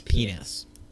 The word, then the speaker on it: PNAS: is PNAS.